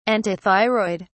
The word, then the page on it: antithyroid: British English